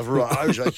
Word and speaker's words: Farage: Dat was die grappige Nigel Farage, zeg ik het goed? – Ja, Farage.